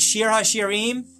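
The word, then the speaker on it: Shir HaShirim (שיר השירים): In Hebrew, it's called Shir HaShirim, which is how you say all that in Hebrew.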